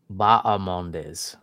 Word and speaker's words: Bahamondes: Apparently the H is silent. Bahamondes.